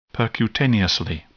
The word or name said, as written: percutaneously